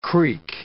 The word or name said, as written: creak